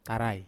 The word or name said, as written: tarai